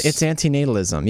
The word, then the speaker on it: antinatalism: No, it's antinatalism. Yes, that's how you pronounce it.